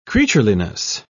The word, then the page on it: creatureliness: British English